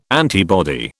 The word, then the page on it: antibody: British English